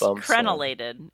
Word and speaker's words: crenulated: I think it's just crenulated, isn't it? – Cren. I said it wrong. Yeah, you're right. Crenulated.